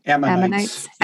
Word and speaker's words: Ammonites: Ammonites.